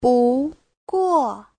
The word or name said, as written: bu2guo4